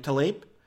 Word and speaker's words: Tlaib: I believe that's how you pronounce her name, Tlaib.